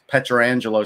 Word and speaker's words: Pietrangelo: But I do think like a guy like Alex Pietrangelo, see, I pronounced that right, Mike, so John Shannon doesn't get on me.